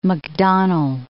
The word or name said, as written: Macdonnell Ranges